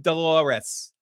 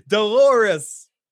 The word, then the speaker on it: Dolores: But instead of hearing shut the fuck up, Phil, I hear wake up, Dolores. It's a name that rhymes with a female body part. Dolores.